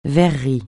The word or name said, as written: verrerie